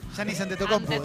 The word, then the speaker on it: Giannis Antetokounmpo: Giannis Antetokounmpo, de Milwaukee Bucks.